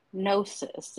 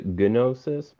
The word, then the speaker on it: Gnosis: It is called The Epic of Gnosis. Am I correct? – Yep, you pronounced it right. Yep. Some people pronounce it gnosis, but yeah, I typically pronounce it gnosis.